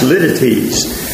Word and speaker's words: litotes: It's spelled like litotes, L-I-T-O-T-E-S. It looks like litotes. It's pronounced lidities.